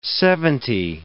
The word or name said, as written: seventy